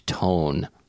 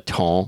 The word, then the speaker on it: ton: T-O-N, the ton. – Oh, I've never heard that. – Le bon ton. – Oh, like roulet le bon ton. – Exactly. – Was it roulet le bon? – Except T-O, that's T-E-M-P-S, and this is T-O-N. But other than that, okay. It's exactly the same.